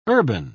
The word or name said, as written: urban